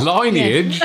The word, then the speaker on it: lineage: Is it not pronounced lineage? – No, it's lineage, mate. – Lineage. Okay. – Exactly how you said it the first time you said it.